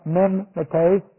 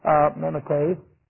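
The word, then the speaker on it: mimetes (μιμηταί): And this is M-I-M, long E, and then T, long E-S, mimetes. And we get the idea of mimic from this word. So we have M-I-M, and then a long E sound, T, and then a long E sound, S, mimetes.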